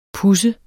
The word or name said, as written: puds